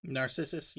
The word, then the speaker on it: Narcissus: Narcissus. Am I saying it right? – Narcissus. Yeah.